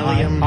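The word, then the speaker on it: bdellium: Yeah, delium. Aisle, like a theater. – Oh, right, okay, and then delium? – Delium, it's a gum-like tree resin. It starts with silent B.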